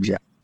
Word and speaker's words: Geoff: Oh, Geoff. Yes, that kind of Jeff. Would you say he spells it wrong? – Yes, he does. In fact, Baseball Reference even has a pronunciation for his first name, J-E-F-F.